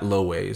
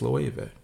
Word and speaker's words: Loewe: It's not Loewe, it's Loewe.